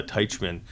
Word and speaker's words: Teichman: Can you just set the record straight on the pronunciation of your last name? – Teichman. – Yeah, because I was right. – Yeah. – Okay. – But it doesn't spell like that very nicely, but. – And I've got Len here. – Lenz. – Lenz, okay. – L-E-N-Z.